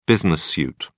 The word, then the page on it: business suit: British English